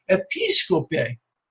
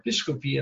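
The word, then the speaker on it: episkope (ἐπισκοπή): The word office there, translated in the RSV is office, the Greek word there is episkope. Episkope, this is the episcopal office, to use the English language in a sense. Episcopal office, the Greek word that episkope, or episkopi in the modern Greek pronunciation.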